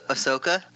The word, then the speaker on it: Ahsoka: Oshaka. Ahsoka. – Ahsoka.